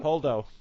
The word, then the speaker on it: Holdo: For one, Poe is going against his orders, and it's actually really nicely setting up how he treats Admiral Hordle. Oh, I can never say her name. – Holdo. – Thank you. I can never pronounce her name right.